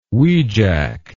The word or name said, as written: wejack